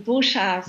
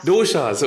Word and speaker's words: Doshas: Ich habe mich ein bisschen schlau gemacht, selbstverständlich, und da bin ich auf die drei Doshas gekommen. Ich hoffe, ich habe es richtig ausgesprochen. – Ähm, Doshas. – Doshas, okay, alles klar.